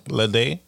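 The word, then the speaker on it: Ledé: Lede.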